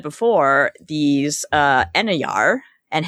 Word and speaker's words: Einherjar: So as we said, Kraft is surrounded by his homies, the, how do you pronounce it, Enyar? Enyar? – Einherjar? – Einherjar.